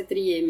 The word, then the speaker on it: Triet: É, a Justine Triet é francesa, então muito provavelmente a pronúncia é Triet mesmo.